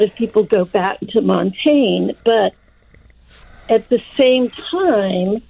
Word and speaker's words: Montaigne: Um, a lot of people look to Michelle de Montaigne. Well, that's not. I'm not saying it right de mm-hmm. Montaigne, right. Michel de Montaigne, mm-hmm.